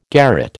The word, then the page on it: garret: American English